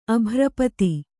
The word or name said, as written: ಅಭ್ರಪತಿ